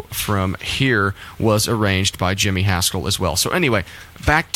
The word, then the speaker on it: Jimmy Haskell: I've failed to mention the orchestrations before, but this track was arranged by Jimmy Haskell. Is that how you pronounce that, Barry? – Yes.